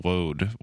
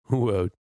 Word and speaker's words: woad: Didn't have specific words for blue until woad was introduced into Europe. – Woad. But what was everything that you learned, other than that woad rhymes with bow, sort of? – Oh, that was it.